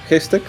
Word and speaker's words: Hextech: Hextech.